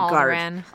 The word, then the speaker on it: Alderaan: Alderaan.